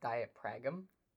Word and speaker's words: diaphragm: I speak from the diagram. The diagram. – The diagram. I speak from my diagram more than Dermot does. So. Mm. Uh, it's pronounced diaphragm.